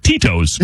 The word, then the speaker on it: Tito's: Pronunciation, Tito's. Tee-dash-T-O-E-S. – Okay, Tito's. No, but that's right.